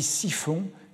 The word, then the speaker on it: chiffon: Alors, euh, là, il y a une confusion, puisque chiffon, euh, prononcé euh, avec la prononciation du Midi, du patois d'Arles, ça se dit siphon, et cette chiffonnerie avait pris pour emblème un siphon, que voici.